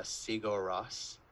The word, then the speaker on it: Sigur Rós: Okay, so um, there's an Icelandic band called uh, Sigur Rós.